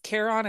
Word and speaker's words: Charon: So what you're probably thinking of is, I think it's Charon is how you say his name, who ferried souls across the river Styx.